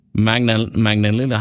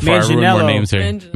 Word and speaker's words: Manganiello: Joe Manganiello. How do you say his last name? How did you guys do it? – Manganiello.